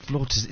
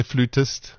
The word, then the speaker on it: flautist: Flautist. – Is it The Americans say flutist and the English say flautist.